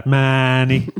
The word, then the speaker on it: Mahony: I think that's the thing, because how would you say it in Cork? – Mahony. – Yeah, Mahony. I mean, it's a real very particular Cork way of saying it, where we kind of say almost like M-A-A-A-A-N-Y, Mahony.